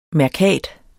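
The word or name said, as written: MÆRKAT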